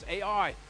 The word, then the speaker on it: Ai: what happens next is they enter the land of Ai, or Ai, however you pronounce it. It's Ai.